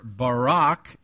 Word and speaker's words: Barak: Why are Barak, and by the way, that's how they pronounce it in Israel, the Jewish people, so that's how I'll pronounce it.